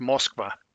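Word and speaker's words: Moskva (Москва): Although we can't really gloat at that one because in Russian it's pronounced Moskva.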